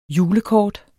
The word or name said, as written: julekort